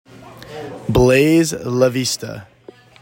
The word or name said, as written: BLAISE LAVISTA